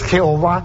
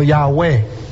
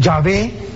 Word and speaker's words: Yahweh: Estas cuatro palabras, estas cuatro letras, la pronunciación que se cree que es la más correcta no es Jehová, sino Yahweh. Los católicos dicen Yahvé,